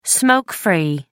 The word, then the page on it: smoke-free: American English